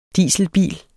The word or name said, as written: dieselbil